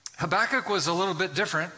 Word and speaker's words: Habakkuk: To say it properly, it's almost like if you imagine yourself coughing up a hairball, you can say it properly. You want to try it? Go ahead. Habakkuk.